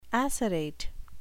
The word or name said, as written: acerate